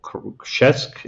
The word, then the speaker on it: Krzesk: I'm probably going to mispronounce this. Is it Krzesk? – Yes, it's a little village in the eastern part of Poland, which is called Krzesk in Polish.